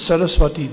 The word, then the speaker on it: Saraswati: No, it's not Saraswati. It's Saraswati Deve. It is a samasa. So it's a short I.